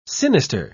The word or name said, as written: sinister